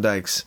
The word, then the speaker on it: Dyche: Sean Dyche's their coach. They got... Did I say that right? – Not quite, but that's all right. – Well, we'll correct me. – Dyche? Yeah.